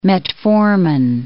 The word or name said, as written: metformin